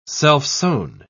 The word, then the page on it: self-sown: British English